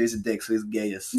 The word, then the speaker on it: Gaius: It's G-A-I-U-S. Mm-hmm. I don't know if it's, is it pronounced Gaius? – Gaius? – Gaius? – Gaius. – Let's be real. Julius Caesar, it's Gaius.